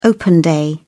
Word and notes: open day: British English

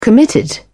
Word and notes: committed: British English

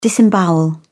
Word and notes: disembowel: British English

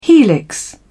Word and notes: helix: British English

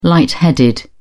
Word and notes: light headed: British English

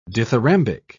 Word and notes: dithyrambic: British English

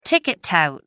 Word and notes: ticket tout: American English

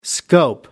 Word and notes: scope: American English

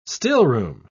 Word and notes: stillroom: British English

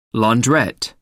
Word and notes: launderette: American English

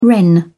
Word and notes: wren: British English

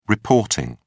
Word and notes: reporting: British English

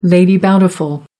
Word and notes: lady bountiful: American English

